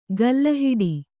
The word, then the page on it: ಕಲ್ಲುಹೆಟ್ಟೆ: Kannada